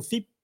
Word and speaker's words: FIP: Autre petite question peut-être anecdotique, mais en fait, on dit FIP, on dit F-I-P-H-F-P, pas forcément facile à prononcer. Qu'est-ce qu'on est censé dire ? – Alors, nous disons FIP parce que tout le monde se perd dans cette abréviation.